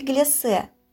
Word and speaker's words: глиссе: Это кофе глиссе. Обратите внимание на то, как я произнесла это слово. Согласно орфоэпическим нормам современного русского языка, это слово произносится с твердым звуком «с» на конце.